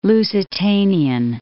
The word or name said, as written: Lusitanian